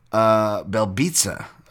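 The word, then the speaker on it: Belbiță: taking Liana Jojua over Diana Belbita, Belbitsa, apparently, with a silent Z there, taking Jack Shore over Aaron Phillips.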